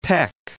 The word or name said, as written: Fort Peck Lake